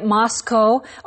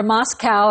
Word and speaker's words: Moscow: Now, you may have noticed already, students, that Steve pronounces it Moscow or Moscow, and I've said Moscow, and both are correct. People will use both. – Right. It's okay. You can pronounce it both ways.